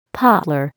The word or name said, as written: poplar